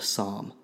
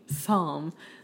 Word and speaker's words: SAHM: You're saying it wrong. It's called a SOM. – Oh. – That's their new acronym. – Oh, okay. – S-A-H-M. – Okay. SOM.